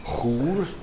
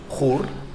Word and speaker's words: Chur: On dit quoi en français, mais dans les autres langues, comment faut-il prononcer le nom de la ville ah, Il y a différentes possibilités. Euh, en euh, allemand, vous dites Chur. – Chur. – En patois, vous dites Cour. en roman, Squera.